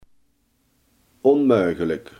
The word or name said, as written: onmogelijk